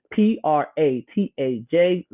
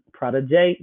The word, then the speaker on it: Protégé: And so follow me on Instagram, Twitter, and Clubhouse at Protégé the Brand, Pratajay the Brand. Prada, like the brand I can't afford, J, like the letter, Prada J, that's how you say my name.